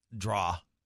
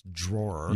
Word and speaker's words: drawer: We got two texters that said you mispronounced draw. It's drawer. – Yeah, drawer. – That's how they say it by the hopper. The draw by the hopper. – No, drawer.